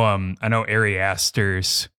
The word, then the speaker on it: Ari Aster: I know Ari Aster's, that's how you say his name, right? – Yeah.